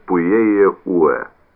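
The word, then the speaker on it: Puyehue: Это был Пуэ-Уэ, произносится Пуэ-Уэ.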